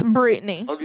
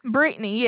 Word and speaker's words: Brittany: My name is Brittany. – Brittany? – Yes, Brittany. – Brittany. How do you spell it? – B-R-I-T-T-A-N.